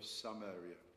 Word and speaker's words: Samaria: The woman of Samaria is how it's pronounced.